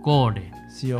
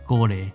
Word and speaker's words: col: 为什么？因为它传说，因为它这个菜就是欧欧洲话了，荷兰话叫 col，西班牙话叫 gole，C O L。你你如果把 C O L 你把它放到 Google 哦，那你给它用西班牙文哦，你听到发音，你可以试一下，那是 gole，留下台语嘛哦。